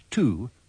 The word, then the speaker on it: to: In these sentences, remember to pronounce to with the schwa.